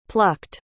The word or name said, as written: plucked